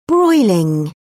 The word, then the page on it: broiling: British English